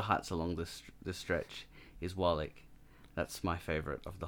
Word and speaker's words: Waalegh: Yes, and using the correct pronunciation as well. It's like you look at it the first time, and I used to say Wally. – Yeah, me too. – Because it is W-A-A-L-E-G-H.